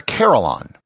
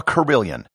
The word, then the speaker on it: carillon: On top of that, one of the instruments was a carillon, or as it's pronounced in England, a carillon.